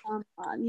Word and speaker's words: Shyamalan: Shyamalan. Sorry, I'm butchering that. – M. Night Shyamalan, I think. – Shyamalan, yes.